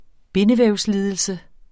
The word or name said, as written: bindevævslidelse